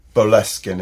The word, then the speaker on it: Boleskine: Yeah, and the awareness that one had that he had bought Crowley's Boleskine, is that how you pronounce it? – Yeah, Boleskine House.